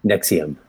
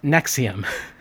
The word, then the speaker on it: NXIVM: NXIVM. – NXIVM.